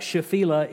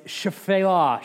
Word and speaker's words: Shephelah: It looks like Shephelah, it's Shephelah, Shephelah. The first E is barely even pronounced.